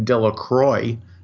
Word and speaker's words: Delacroix: Bobby and Harry Jones and Dickie Delacroix, the villagers pronounce this name Delacroix, C-R-O-Y instead of C-R-O-I-X,